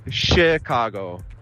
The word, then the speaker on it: Chicago: Obwohl normalerweise CH auf Englisch CH ausgesprochen wird, eigentlich ist es Chicago, als ob es SCH wäre.